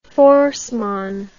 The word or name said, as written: Forssman